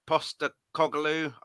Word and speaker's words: Postecoglou: my choice is Postecoglou.